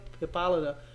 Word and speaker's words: Hippolyta: Queen Hippolyta gets with, is that how you pronounce it? – Hippolyta